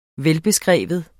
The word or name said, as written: velbeskreven